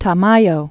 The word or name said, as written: Tamayo